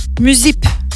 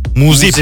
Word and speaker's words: muzip: Mesela müzip. – Muzip.